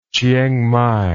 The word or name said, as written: chieng mai